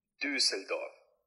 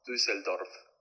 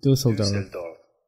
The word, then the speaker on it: Düsseldorf: La R tampoco la pronuncias tanto. O sea, no es como Düsseldorf. O sea, puedes pronunciarla, pero si quieres verte así como mamón, sí sería como Düsseldorf. Düsseldorf. Düsseldorf. Düsseldorf. Es más, ni siquiera pronuncias la R si quieres.